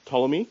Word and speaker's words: Ptolemy: It's a silent P, Ptolemy, which is Egypt in the green.